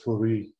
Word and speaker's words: Fourie: Yes, it's Tertius, and my surname is Fourie, yes.